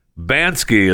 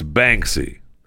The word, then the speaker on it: Banksy: So remember the, and I keep calling him Banks. Now I can't even say his name. Banksy. Banksy is the artist. I keep calling it Bansky. Bansky is not correct. It's Banksy.